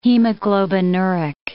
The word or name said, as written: hemoglobinuric